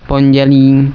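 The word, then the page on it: arakõi: Guarani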